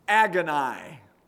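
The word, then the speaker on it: agony (ἀγωνία): That word conflict is a translation of a Greek word that's pronounced agony, which sounds like what? The English word that we get from that Greek word, agony.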